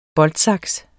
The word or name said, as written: boltsaks